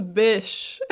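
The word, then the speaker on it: Bysshe: So let's start with Harriet Westbrook and Percy Shelley, whose middle name is conveniently pronounced as Bish.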